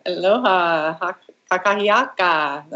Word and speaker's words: Aloha kakahiaka: So how do you say that again? – Aloha kakahiaka. It's all one word.